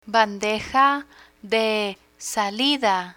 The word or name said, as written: bandeja de salida